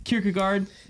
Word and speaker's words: Kierkegaard: So we just got done with Kierkegaard.